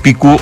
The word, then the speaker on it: picú: Uso coloquial, la pronunciación española, picú o pick-up,